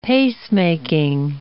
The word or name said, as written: pacemaking